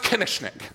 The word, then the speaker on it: Koenigsknecht: My mom's maiden name, I've told you about this before, my mom's maiden name was K-O-E-N-I-G-S-K-N-E-C-H-T. It is pronounced exactly as it's spelled. I don't know what else to do for you, but it's pronounced Kinnishnik.